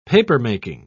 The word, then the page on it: papermaking: British English